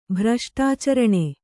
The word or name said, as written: ಭ್ರಷ್ಟಾಚರಣೆ